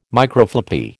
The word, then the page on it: Microfloppy: American English